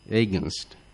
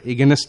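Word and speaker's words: against: এরপরে আমরা যে শব্দটি ব্যবহার করব সেটি হলো এগেনস্ট, এগেনস্টনেসে বিরুদ্ধে বা বিপরীতে, এগেনস্ট, আমরা এগেনস্ট বলবো না, আমরা উচ্চারণটা হবে এগেনস্ট, এগেনস্ট।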